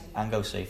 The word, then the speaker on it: Anglesey: He was visiting, now I can't pronounce 12 places here very well, but is it Anglesey? Is that any of you know how to pronounce that? Anglesey, thank you.